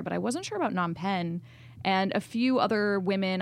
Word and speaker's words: Phnom Penh: Phnom Penh, but... – That's right, Phnom Penh.